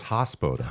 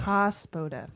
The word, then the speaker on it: Hospoda: Did I say it right? – I never know. – Yeah. I know it's like, no, it's Hospoda. – Hospoda.